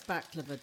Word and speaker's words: baklava: Is that how you say it? – Baklava. – Baklava? – Well, I don't know. You're not fucking great. I think it's baklava. I know it's baklava, darling.